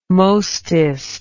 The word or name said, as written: mostest